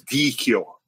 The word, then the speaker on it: Dichio: Okay, so CH in Italian is key. So it's Dichio. Dichio. No, it is. It's only because my dad told me that. Because in England, they pronounce CH differently. It's ch.